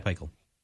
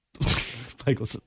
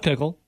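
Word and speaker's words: Pikiell: But yeah, Rutgers head coach, Pikel, not Pickle.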